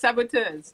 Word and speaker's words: Saboteuse: Was I saying that right? La Saboteuse? – You were. It's Saboteuse.